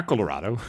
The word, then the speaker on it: Colorado: En daar in Colorado, hoe spreek je dat uit? – Ja, Colorado.